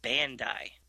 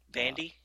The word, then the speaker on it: Bandai: And there's a there's a toy company called Bandai, and it's spelt that way. It's a Japanese toy company, so I just assume that's how you would pronounce it.